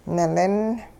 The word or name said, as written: nilin